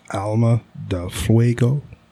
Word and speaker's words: Alma de Fuego: My number one is the Placencia Alma de Fuego. – I think you're saying that wrong. – Alma de Fuego. It is. Pronounced A-L-M-A, Alma, D-E-L, Del, F-U-E-G-O, Fuego.